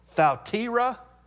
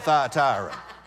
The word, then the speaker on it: Thyatira: I think it's actually properly pronounced Thyatira, but since we're in Montgomery County, we call it Thyatira.